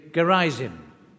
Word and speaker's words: Gerizim: The other was called Mount Gerizim. Maybe our English pronunciation would be Gerizim.